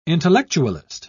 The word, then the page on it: intellectualist: British English